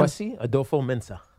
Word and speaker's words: Kwesi Adofo-Mensah: Kwesi Adofo-Mensah.